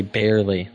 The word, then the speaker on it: Baierle: Bayer, like a Bayer aspirin with an L-E, barely. Like I barely.